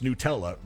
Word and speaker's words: Nutella: But the people who created the company say it's pronounced Nutella. They're the ones that get to tell us how to pronounce it.